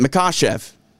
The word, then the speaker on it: Makhachev: I think Makhachev, is that the way you pronounce his name? – I guess I try to say how Anik says it. Makhachev.